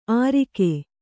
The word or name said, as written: ಅರಿಕೆ